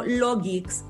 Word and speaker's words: LawGeex: Eh, otro, por ejemplo, Logix es otro que se inventó en Israel. Se escribe L-A-W-G-E-E-X.